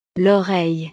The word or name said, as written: l'oreille